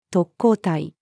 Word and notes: 特攻隊: female

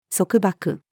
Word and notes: 束縛: female

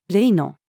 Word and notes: 例の: female

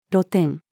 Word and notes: 露店: female

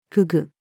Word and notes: 河豚: female

